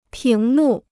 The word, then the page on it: 屏幕: píng mù